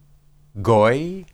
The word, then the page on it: goy: hebrew